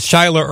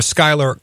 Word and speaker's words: Schuyler: I guess it's Schuyler or Schuyler Colfax. I guess you could use either pronunciation.